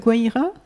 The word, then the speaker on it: Guaira: Bah, ma première, j'avais la Guaira. Oui, on prononce comme ça.